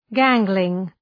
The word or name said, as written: gangling